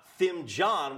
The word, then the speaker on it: Thimjon: Your last name is spelled T-H-I-M-J-O-N. It's pronounced Thimjon, not Thimjon, which is how it looks.